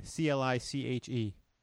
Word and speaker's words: cliché: C L I C H E.